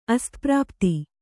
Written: ಅಸ್ತಪ್ರಾಪ್ತಿ

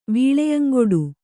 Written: ವೀಳೆಯಂಗೊಡು

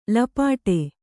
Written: ಲಪಾಟಿ

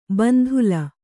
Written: ಬಂದಳ

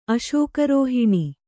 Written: ಅಶೋಕರೋಹಿಣಿ

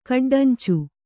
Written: ಕಡ್ಡಂಚು

